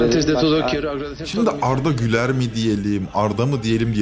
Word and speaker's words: Güler: Ama Arda Güler. Normalde Ü ile Arda Güler.